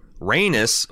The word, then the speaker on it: Rhaenys: where you guys were debating the meaning behind Rhaenys, almost pronounce it wrong, Rhaenys busting through the boards on her beast.